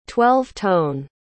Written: twelve-tone

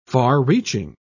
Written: far-reaching

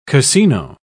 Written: casino

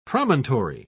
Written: promontory

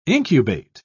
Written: incubate

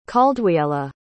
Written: caldwiella

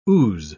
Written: ooze

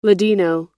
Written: ladino

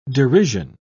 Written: derision

